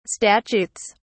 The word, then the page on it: statutes: British English